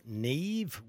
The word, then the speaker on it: Niamh: Uh, N-I-A-M-H is pronounced Neve.